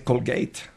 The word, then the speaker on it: Colgate: Bueno, no se dice Colgate, se dice Colgate.